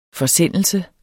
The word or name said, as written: forsendelse